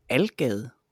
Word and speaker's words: Algade: Og her i Roskilde, der hedder den Algade, øh, AL-gade.